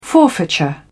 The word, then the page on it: forfeiture: British English